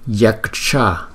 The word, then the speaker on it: yaksha: Đó, thì chúng ta đọc chữ này là yaksha, chữ K rất là yếu nên là yaksha yaksha Yakshagraha.